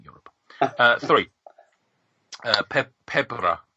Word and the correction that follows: pebre: I don't know how to pronounce this. Pebre. – Michael will tell you. – Could you remind Pebre? – Right, yeah. Remind me, what's in it?